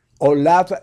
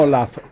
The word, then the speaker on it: Olav: Olav de Noruega. No Olav es Olav. Oh, seguramente se ha de pronunciar así la, la V.